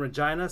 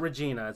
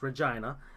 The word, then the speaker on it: Regina: So it'll be in Regina, Saskatchewan. – And no, he actually is pronouncing that correctly. – Yeah, it's not Regina, it's Regina.